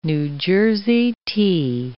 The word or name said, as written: New Jersey tea